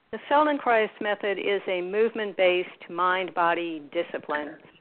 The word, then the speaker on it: Feldenkrais: And as I understand, you're also a practitioner, I guess, a teacher of the, and help me say this correctly, the Feldenkrais method? – Feldenkrais method, that's correct. Yes.